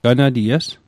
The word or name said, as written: ganaties